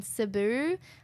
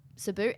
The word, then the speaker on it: Cebu: I was doing some pretty outrageous things, traveling in like Manila and Cebu. I know I say it wrong. It's Cebu.